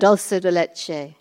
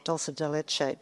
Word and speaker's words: dulce de leche: Dolce leche? Is that how you say it? – Dulce de leche. – Dulce de leche.